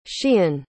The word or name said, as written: sheehan